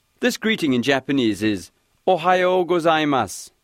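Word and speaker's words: Ohayo gozaimasu (おはようございます): The first part actually rhymes with the name of the Midwestern state, Ohayo. After that, there's a part that sounds like go. So Ohayo go will give you a head start on this one.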